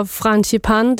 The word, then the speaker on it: frangipane: Det hedder frangipane. Det var frangipane.